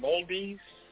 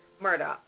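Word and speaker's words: Murdaugh: Moldes? I think you pronounced it. Moldes? Alex Moldes? – Murdoch.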